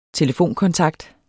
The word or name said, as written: telefonkontakt